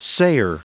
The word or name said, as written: sayer